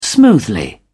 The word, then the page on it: smoothly: British English